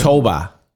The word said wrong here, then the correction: Tobol: How do we pronounce this? – Is it not Tobol? – Tobol, yeah.